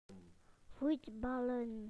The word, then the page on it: to play football: British English